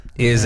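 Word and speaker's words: Hergé: Eze is the pseudonym for Belgian cartoonist Jules de Rimé, creator of The Adventures of Tintin. The name originates from the French pronunciation of his reversed initials, RG.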